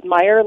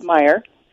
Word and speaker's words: Mayer: Yeah, first, my last name is pronounced Meyer, like the hot dogs, like Oscar Mayer hot dogs and bacon and bologna that everybody knows. So it's Alex Mayer.